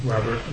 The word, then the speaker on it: Robert: Brian Robert Leach. Brian, B-R-I-A-N, Robert, R-O-B-E-R-T, Leach, L-E-A-C-H, and my title is the Chief Risk Officer of Citigroup.